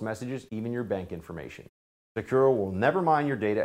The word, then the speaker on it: Sekur: You need a new cybersecurity and privacy application tool called Secure. It's spelled S-E-K-U-R.